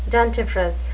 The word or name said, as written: dentifrice